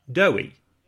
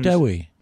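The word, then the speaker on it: Dewi: You have to imagine Debbie. That it's Debbie, but you replace the B's with a single W. So it becomes Dewey. – Dewey.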